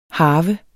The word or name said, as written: harve